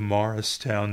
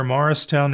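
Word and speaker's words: Mauricetown: Come one, come all to Cumberland County Artist Day, and that is in the historic village of Morristown, New Jersey. You all know where Morristown is. You all know that it's spelled M-A-U-R-I-C-E, Morristown, south of Millville, north of Port Norris.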